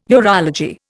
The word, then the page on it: urology: Indian